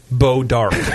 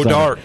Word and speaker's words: Bois d'Arc: Bois d'Arc, B-O-I-S-D apostrophe A-R-C, Bois d'Arc. – Yeah. – It's pronounced Bo Dark. – I love Missouri all the time. – Bo Dark. – I can't wait to go. – Bona, B-O-N-A, Bunna.